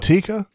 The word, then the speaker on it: Tikka: T-I-K-K-A? – Oh, yeah. I know what you're talking about. – Is it Tika? – I think that's right. – I think that's right. – Tika or Tika? – No, I think it's Tika.